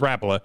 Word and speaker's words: Rapala: Is it Rapala or is it Rapala? But it is Rapala is the proper way to say it.